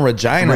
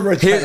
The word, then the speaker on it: Regina: Keep your Regina clean. – Here's the shit. I thought it was Regina, Saskatchewan. And I had a show in Calgary. – They're like, no. It rhymes with vagina.